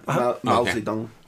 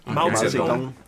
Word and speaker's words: Mao Zedong: Mao Zedong. – Mao Zedong.